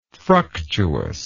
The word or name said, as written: fructuousness